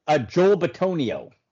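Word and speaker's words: Joel Bitonio: I believe Joe, might not be the right pronunciation, but Benito? – Joel Betonio. – Betonio, yes.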